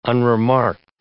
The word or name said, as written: unremarked